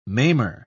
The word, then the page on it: maimer: British English